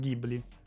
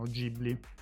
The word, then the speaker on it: Ghibli: Ma, eh, Miyazaki si era, si era, basato su un nome italiano, quindi dovrebbe essere Ghibli, soltanto che se poi vai a vedere i giapponesi lo pronunciano Ghibli.